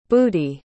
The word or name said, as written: boody